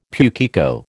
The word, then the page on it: pukeko: American English